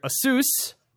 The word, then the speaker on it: ASUS: HP, Dell, Lenovo, I would say the name wrong here. Asus. Say it correctly. At least that's according to the YouTube pronunciation page.